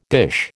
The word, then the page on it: dish: American English